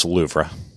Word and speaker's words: Louvre: I think it's pronounced Louvre.